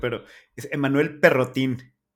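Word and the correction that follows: Perrotin: Pero, perrotán.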